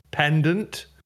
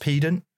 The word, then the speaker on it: pedant: At first, when I read it, I thought he was like giving it to someone who had like the biggest pendant. – Yeah, I think you'll find it's pronounced pedant.